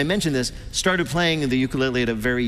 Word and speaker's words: ukulele: Peter's first question to Taimane: how the hell do you pronounce ukulele? – Ukulele.